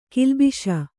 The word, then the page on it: ಗುಲ್ಲೆಬ್ಬಿಸು: gullebbisu